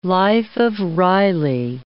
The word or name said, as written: life of Reilly